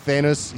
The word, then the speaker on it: Thanos: He was on Thanos. He created Thanos, is right how you pronounce it.